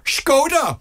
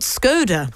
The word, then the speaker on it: Skoda: Skoda are keen to know it's pronounced actually... – Skoda! – Not Skoda.